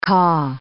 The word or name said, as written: ca'